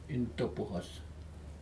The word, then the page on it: intupohos: Aneityum